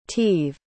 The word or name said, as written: tieve